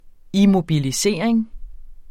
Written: immobilisering

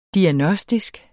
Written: diagnostisk

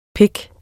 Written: pik

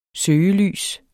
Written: søgelys

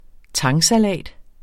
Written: tangsalat

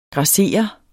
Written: GRASSER

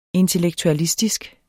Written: intellektualistisk

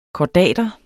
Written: kordater

